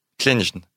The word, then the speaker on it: Kleijn: Ouais, c'est dur à prononcer parce que c'est K-L-E-I-J-N. Klenge.